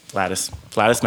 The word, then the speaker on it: Flatus: Dave Smooch, which is Phineas Maximus. Did I say that right? – Corey Smoot? Flatus. – My bad. Flatus Maximus.